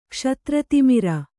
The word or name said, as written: ಕ್ಷತ್ರತಿಮಿರ